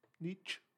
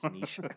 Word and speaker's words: niche: But I used to pronounce it with the hard ch. – Niche. – Yeah. But then I was like, no, I'm a fool. It's niche.